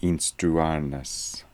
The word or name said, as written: Instruarness